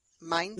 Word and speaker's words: Mainz: Mainz.